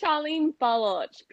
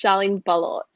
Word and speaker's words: Sharlene Baloch: it's Sharlene Baloch. People say Baloch. Sometimes I get bleach, but it's Sharlene Baloch.